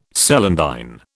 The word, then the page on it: celandine: British English